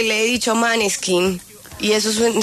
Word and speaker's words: Måneskin: Måneskin. – Debí haber quedado con Måneskin, correcto. Es como, sí, como una pronunciación rápida, pero más como...